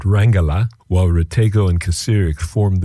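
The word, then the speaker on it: Kacirek: It's a duo consisting of German electronic experimental drummer Sven Kasirek. That's K-A-C-I-R-E-K, Sven Kasirek, and singer-songwriter and instrument maker Olith Rategó, R-A-T-E-G-O.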